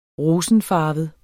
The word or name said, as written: rosenfarvet